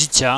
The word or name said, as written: dzicia